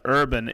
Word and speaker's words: URBN: They're on Instagram at Urban Timber, but Urban is spelled URBN, and it's Timber, TIMBER, and it's Urban Timber, same spelling, .com.